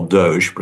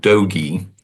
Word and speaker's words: DOGE: which you may call Doge, but I find that a sort of French pronunciation. I prefer the Southwestern Doge, as in get along little dogies.